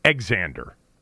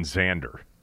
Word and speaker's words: Xander: Yes, I think X-A-N-D-E-R would be Xander, would be the correct pronunciation. It wouldn't be Exander, I don't think. Like X-A-V-I-E-R is Xavier, not Xavier.